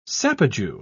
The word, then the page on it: sapajou: British English